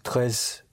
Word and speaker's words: très: Très différent. – Like that. – Comme ça. – Right. Ce n'est pas très différent comme ça. Mm-hmm. Yes. But it is very important for me. – Mais c'est très important pour moi.